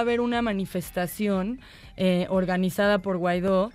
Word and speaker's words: Guaidó: Es con G-U-A-I-D-O, acento en la O, Juan Guaidó.